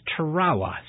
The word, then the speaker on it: Tarawa: The Americans pronounce it Tarawa. The old Marines corrected me, a gentleman that came in a landing craft, and he goes, "No." He says it's pronounced Tarawa, sonny. And the way you remember that is the old song, Tarara Boom, yay.